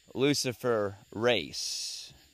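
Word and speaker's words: Luciferase: Luciferus. Luciferus, or pronounced Lucifer race.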